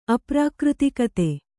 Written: ಅಪ್ರಾಕೃತಿಕತೆ

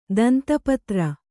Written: ದಂತಪತ್ರ

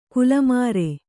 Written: ಗಲ್ಲುಮರ

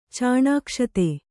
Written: ಚಾಣಾಕ್ಷತೆ

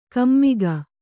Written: ಕುಮಕ್ಕು

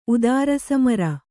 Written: ಉದಾರಸಮರ

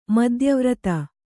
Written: ಮಧ್ಯವರ್ತಿ

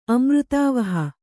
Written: ಅಮೃತಾವಹ

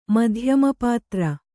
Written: ಮಧ್ಯಮಪಾತ್ರ

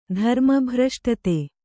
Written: ಧರ್ಮಭ್ರಷ್ಟತೆ

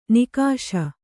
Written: ನಿಕಷೆ

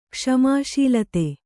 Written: ಕ್ಷಮಾಶೀಲತೆ

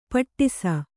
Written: ಪಟ್ಟಿಸ

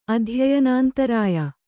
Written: ಅಧ್ಯಯನಾಂತರಾಯ

